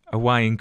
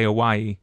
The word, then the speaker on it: Hawaii: it's actually hawaii hawaii, hawaii. so hawaii. is like a v yeah hawaii but um because there's like a, a little thing glottal stop we call it okina between the two eyes so yeah, no, nobody else does it correctly.